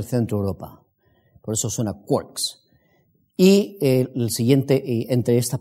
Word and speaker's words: quarks: Los quarks, esto se pronuncia como si aquí hubiese una O, quarks, y los leptones.